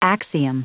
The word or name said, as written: axiom